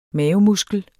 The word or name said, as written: mavemuskel